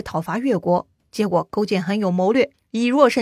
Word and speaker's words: 勾践: 勾践啊，勾心斗角的勾，实践的践，左传中勾写成句号的句，说起来呢是个多音字啊，在此处呢应该也是读勾的，或许呢这个也是有争议的，反正呢咱就按照传统历史课本啊，这一位呢就念成勾践了。